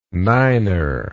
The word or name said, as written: niner